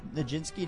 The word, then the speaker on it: Nijinsky: Kip Winger's our guest, and that is the opening movement to Conversations with Nijinsky.